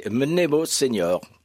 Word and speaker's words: Mnemo Senior: Est-ce que ça se prononce comme ça d'ailleurs, le nom de l'association? – Alors l'association c'est Mnemo Senior.